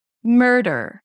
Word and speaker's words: murder: So basically, it sounds like it's the same vowel, murder. The primary stress falls on the first syllable. That's why it's a little higher in pitch. Murder. The D is very light.